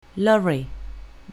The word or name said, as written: lorry